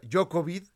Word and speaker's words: Djokovic: Se hace una maraña y eh, Djokovic, que además se, se en serbio se pronuncia Jokovid.